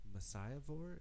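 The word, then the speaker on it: Messiahvore: But you guys are all from the band Messiah Vore. Am I saying that right? – You are. – Mm-hmm. – It's like Messiah Whore, but with a V.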